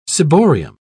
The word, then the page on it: ciborium: British English